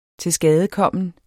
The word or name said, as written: tilskadekommen